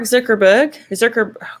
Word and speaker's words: Zuckerberg: Anyway, Mark Zuckerberg, how do you say his name? – Zuckerberg.